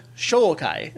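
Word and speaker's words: shōkai: There is indeed a word, Shokai. It's a long O sound.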